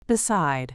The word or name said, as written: beside